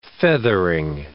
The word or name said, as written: feathering